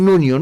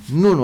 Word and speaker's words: Nuno: numele ăsta portughez, Nuno, îi spun Nunio, nu există niciunul numele ăsta Nunio în portughez. Nuno, așa se, dacă tot vrei să pronunți portughez, dacă nu vrei să spui Pedro Nuno, spui Pedro Nuno.